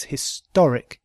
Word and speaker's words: historic: This is an historic moment, because historic, historic, has the stress on the second syllable.